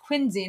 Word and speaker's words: Quincy: I learned it's pronounced Quincy, not Quincy. Quincy Adams.